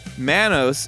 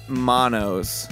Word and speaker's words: Manos: Manos is pronounced Man-O's, not Mono's."